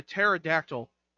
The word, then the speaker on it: pterodactyl: And the pterodactyl. The P is silent.